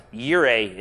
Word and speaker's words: Yireh: Although J-O-L-L-A certainly looks like, never mind. Anyway, Yireh, even though it looks like Jireh, Yireh is how that would be pronounced in the Hebrew.